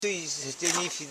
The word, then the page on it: suisdinifi: su.is.di.ni.fi